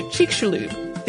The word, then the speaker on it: Chicxulub: By the way, you got the pronunciation pretty close, but it's actually pronounced more like Chicxulub, the exclamation SH sound.